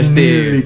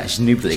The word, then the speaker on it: Schneebly: S-C-H-N-E-E-B-L-Y. Schnibble. – Schnibble. – Schnibble. – Y más difícil pronunciarlo, ¿no? – Que sería algo así como... Como... Schnibble.